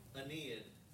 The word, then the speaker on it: Aeneid: Aeneid.